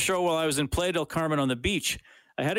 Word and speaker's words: Playa del Carmen: He says, I once listened to your show while I was in, is it Playa del Carmen? Is that how you say it? – Playa del Carmen. – Playa del Carmen.